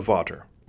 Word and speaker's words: Vater: And Vater is from the German anatomist Abraham Vater. It's not pronounced Vader, and it's certainly not the same as Darth Vader.